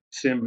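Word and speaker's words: SIEM: So solutions like third-party threat intelligence to understand what's happening, and a category called security information and event management, or SIM is how a lot of people pronounce it.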